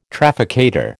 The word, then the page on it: trafficator: American English